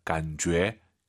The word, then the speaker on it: 感觉: 이 제이유이는요. 주애가 아니에요. 너의 죄입니다. 感觉. 感觉. 이렇게 발음하시기 바랍니다.